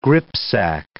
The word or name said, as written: gripsack